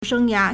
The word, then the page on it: 生涯: shēngyá